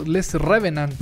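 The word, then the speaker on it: Les Revenants: Estamos hablando de Le Ravement les Revenant o Les Revenant.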